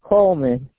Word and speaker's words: Coleman: Coleman. – Spell that, please. – C-O-L-E-M-A-N. – C-O-L-E-M-A-N, Carmen, C-A-R-M-E-N.